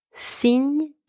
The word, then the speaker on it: cygne: So the first one, spelled C-Y-G-N-E, means swan. The letter C in this word is pronounced S. The letter Y is pronounced E, and the letters G and N together are pronounced Ny, and the final E is silent. Word number two, signe, spelled S-I-G-N-E, means sign.